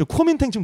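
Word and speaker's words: Kuomintang: 그 지금 국민당 이제 국민당이라고 하는데 저 쿠민탱 지금 너무 때려잡으신 거 아닙니까? – 아니요. 이거는 그 영어식 발음으로. – 어 정말요? – 네네. 오, 서구권에서 그렇구나. 국민당을 부를 때 쿠민탱 이렇게 불렀어요.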